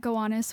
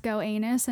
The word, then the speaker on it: Gowanus: Oh, there was a day where I tried to get a rumor going that Gowanus was actually pronounced Go Anus, and I still sure.